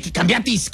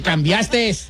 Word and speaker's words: cambiaste: Y se dice, cambiaste. – Okay, no, cambiaste. – Sin ese al último. – Sin ese. – Oh, sí, no se dice cambiates.